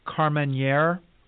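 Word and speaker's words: Carménère: And it looks like something approximating Carmenere and Gros Verdot. Did I say that right, David? – Yes, sir.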